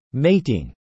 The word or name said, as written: Mating